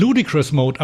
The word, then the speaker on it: Ludicrous Mode: Na, nicht, dass du dich da festhalten musst und runtergeweht wirst sonst, aber ja, also kein Ludicrous Mode. – Nee. – Oder wie wird es ausgesprochen? – Ludicrous Mode, aber du müsstest den kennen.